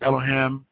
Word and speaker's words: Elohim: All right, he says, the first time the word God was used in the Bible was taken from the word Elohim, pronounced Elohim, and is the plural of Eloah and means gods, but specifically used in the plural, Genesis 1:1.